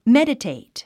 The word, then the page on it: meditate: médətèit; メデテェイト